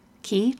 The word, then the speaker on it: qi: I don't know how to pronounce key. It's, you know, that word in Scrabble that you pretend like, you know, Q-I when you don't have a U. – Qi? Qi. It's called Qi, isn't it?